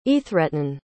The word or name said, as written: aethretun